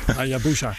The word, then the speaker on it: Hayabusa: Hayabusa.